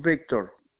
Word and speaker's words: Victor: My first name, Victor.